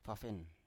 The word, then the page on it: pafen: Biak